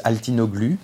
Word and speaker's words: Altinoglu: And of course, I'm French, so it's pronounced now in the French way, which is Altinoglu.